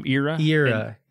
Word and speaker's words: Era: It's a long E, Era.